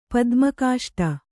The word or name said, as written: ಪದ್ಮಕಾಷ್ಟ